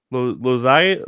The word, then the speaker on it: Loaiza: Loaiza? I think I said it right, right? Loaiza? Loaiza? I think that's how you say it. – I believe you are right.